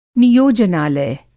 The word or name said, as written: नियोजनालय